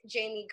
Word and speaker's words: Jaimi: Please follow me on Twitter at It's Jamie Gray, or better yet, on Instagram at Jamie Gray. And then my name is spelled J-A-I-M-I. It's different. G-R-A-Y.